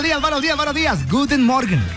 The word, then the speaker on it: Guten Morgen: Guten Morgen. Escuchemos cómo se dice buenos días en alemán. – Guten Morgen. – Eso, sí. Guten Morgen. Dígalo usted, por favor.